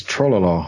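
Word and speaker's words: Trololo: Trollo lyrics. Am I saying that right? – I think it's Trollolo.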